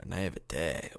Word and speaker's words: naivete: Oh, I mean, you know, young in my naivete of my Spinarax. – Ooh, but, I like the accent you have there. Naivete. Ooh. – That's how you pronounce that word.